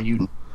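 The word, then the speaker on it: Eiyuden: EI is pronounced I. – Yeah. – Ayuden. – I don't know. – It's Ayuden because AU in Japanese means hero. So here's a story, basically. – It's spelled E-I-Y, not A-I. – Yeah, it's still pronounced that way. Doesn't matter. – Yeah. Take that, you son of a bitch. – Every time I've seen E-I pronounced, it's I. Einstein, you know, Well, you're wrong, because this is Japanese.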